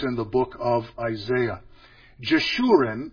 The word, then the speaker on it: Jeshurun: If we would pronounce Israel something like this, Jishrael, then you could see Jeshurun, Jishrael. The J sound, the Sh sound, and the R sound.